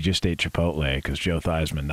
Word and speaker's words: Theismann: Yeah, it's actually Theismann. – Joe Theismann. – Great Golden Domer, Joe Theismann. Yeah. – They changed it to Heisman or Theismann to rhyme with Heisman, you know.